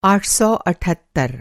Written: आठ सौ अठहत्तर

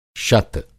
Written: शत

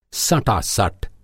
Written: सटासट

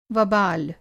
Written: वबाल

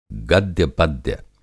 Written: गद्य-पद्य

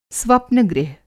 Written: स्वप्नगृह

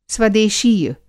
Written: स्वदेशीय

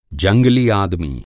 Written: जंगली

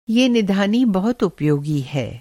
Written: निधानी

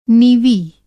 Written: नीवी

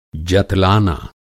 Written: जतलाना

